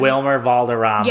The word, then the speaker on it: Wilmer Valderrama: It's Wilderama. – Oh, him. Wilmer Valderrama.